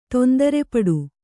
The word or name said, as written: ತೊಂದರೆಪಡು